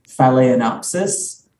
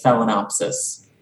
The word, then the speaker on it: Phalaenopsis: the pronunciation of today is, now there's two ways you can say it. There's the, you know, more, you know, true to the Latin pronunciation, even though it's not that true to the Latin. There's Phalaenopsis, or you could say it Phalaenopsis.